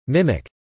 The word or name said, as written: mimic